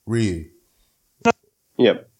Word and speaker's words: Ryu: How would the locals say his name? – Ryu. Yep.